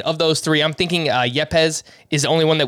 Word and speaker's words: Yepes: Yepes? – That's correct.